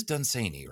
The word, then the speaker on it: Dunsany: And it is Dunsany, right? Is that how we're? – Yes, rhymes with rainy.